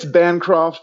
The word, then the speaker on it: Bancroft: It's Bancroft, but like, I'm not really picky.